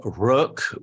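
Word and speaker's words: Rök: correct me on pronunciation here, Ian, Rook Flute? Is that correct? – Well, we say Rook, which is the, with the umlaut, which is the, an old Icelandic word meaning destiny.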